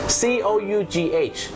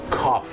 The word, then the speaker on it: cough: คุณผู้ชมครับภาษาอังกฤษเป็นภาษาที่บางครั้งเนี่ยนะครับการออกเสียงไม่แมทกับการสะกดอย่างแรงนะครับเช่นคำว่าอาการไอหรือมีการอาการไอนั่นเองครับ C O U G H ออกเสียงว่า cough cough นั่นเองนะฮะ cough ถ้าเป็นกริยากำลังเกิดขึ้นก็คือ coughing ไม่ใช่ coffee กาแฟไม่ใช่ coffin ลงศพ